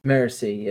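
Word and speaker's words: Marasi: How do you pronounce her name again? Is it Morassi? – Morassi, yes, sir.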